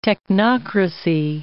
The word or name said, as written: technocracy